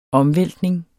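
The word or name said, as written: OMVÆLTNING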